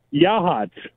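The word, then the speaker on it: Yachats: No, that's Yahat. – Oh. Yeah. – What? – Hey, you say it right. Hey, way to say it right.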